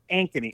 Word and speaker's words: Ankeny: Is it Amke? Is that how you say the name of the town? I think that's how you say it, but it was one of Ankeny. – Ankeny. Okay. Yep.